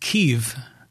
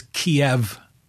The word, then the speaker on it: Kyiv: And it's interesting, too, is you notice how the capital of Ukraine, not of the Ukraine, but the capital of Ukraine is now pronounced Kyiv, where when I was being brought up, it was Kiev.